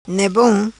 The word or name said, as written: Ne Bom